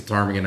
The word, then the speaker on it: ptarmigan: I don't even think it's a ptarmigan. I think it's some other bird, but the P is silent.